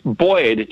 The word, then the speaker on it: buoyed: Buoyed would be... Buoyed, just B-O-Y-E-D. That would be my Midwestern pronunciation. But the buoyed, the bringing in something of the U would be more of a European pronunciation for sure, and probably most places in America, I'm going to guess.